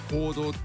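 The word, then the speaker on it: 黄道: ちょうど 27 土曜日はサソリ座の一等星アンタレスのそばにね輝くので、こうしてこうやってね、乙女座、天秤座、サソリ座と渡ってゆくお月様の道、太陽の通り道は黄色い道と書いて行動っていうじゃないですか。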